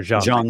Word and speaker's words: Jiang: We're doing what the Stanford University doctor, I can't even pronounce his name, J-I-A-N-G, Dr. Zhang, I think is right.